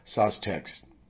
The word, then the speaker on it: Saustex: It's called Sostex. And let me spell that for you because it's kind of trippy. S-A-U-S-T-E-X.